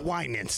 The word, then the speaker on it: Winans: The Winans. Thank you for helping with my pronunciation.